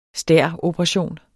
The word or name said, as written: stæroperation